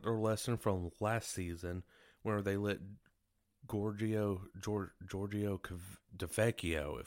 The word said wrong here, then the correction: Tavecchio: if I'm pronouncing that name right. It's been a little while since, you know, we've heard that name, and it was a little hard to pronounce that, but Tavecchio. Let's just say that, Tavecchio.